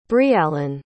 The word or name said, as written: briallen